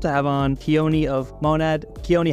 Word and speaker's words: Monad: Oh, it's Monad.